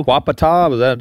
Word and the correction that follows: Quapaw: Did I pronounce that right? – Quapaw. – Quapaw and the Osage. – Yeah, the Caddo, the Osage, and the Quapaw.